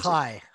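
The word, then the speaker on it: chai (חי): which is it chai? Is that how you pronounce chai.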